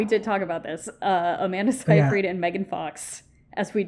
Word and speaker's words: Seyfried: We think Megan Fox and Amanda Seyfried. Did I say her name? I've never said it. – Seyfried.